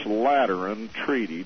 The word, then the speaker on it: Lateran Treaty: By terms of this Lateran Treaty, did I pronounce that right? – You did absolutely correctly so far.